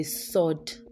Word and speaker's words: sword: So you don't say sword. It's called a sod. Sod, not sword. The W is not pronounced.